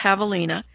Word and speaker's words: javelina: It's spelled like javelin, only it's pronounced javelina.